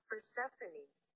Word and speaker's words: Persephone: Persephone.